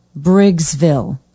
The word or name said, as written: briggsville